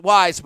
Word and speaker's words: Wyze: Now, it's pronounced Wyze, but it's spelled W-Y-Z-E. That's W-Y-Z-E.